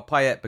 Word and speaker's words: Payet: Well, Payet, because he's from the Réunion, apparently. That's why you do pronounce the T.